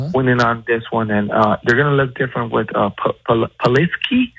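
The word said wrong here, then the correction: Pulisic: Hopefully I said his name right, Brian. – Polisic. – That's the name, right? – Polisic.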